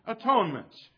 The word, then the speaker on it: atonement: The word has been pronounced in the past at one ment.